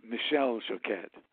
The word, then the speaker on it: Michel Choquette: And I hope I'm not butchering this guy's name with Michael Choquette. – Well, yeah, he's actually French Canadian, so he pronounces it Michel Choquette.